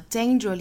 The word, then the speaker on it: danger: 好，回过头我们再看 dangerous 这个词，刚才我们讲到了 danger 里面的这个 a 发双元音的 a 的发音。